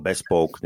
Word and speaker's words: bespoke: to je bespoke, ako, alebo bespoke, neviem, jak sa to vyslovuje no, správne.